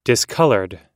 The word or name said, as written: discolored